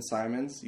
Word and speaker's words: Simons: It's my full name, at Benjamin Simons. You spell my last name with one M, S-I-M-O-N-S.